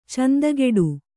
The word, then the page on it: ಚಂದಿಗೋಡೆ: candi gōḍe